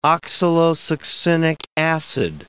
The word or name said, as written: oxalosuccinic acid